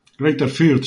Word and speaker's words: Fürth: Greutherfurt, ¿Cómo? ¿Cómo? Greaterfurth. Fürth sería, más o menos.